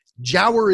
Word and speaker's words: giaour: It's spelled G-I-A-O-U-R, and it's pronounced jower.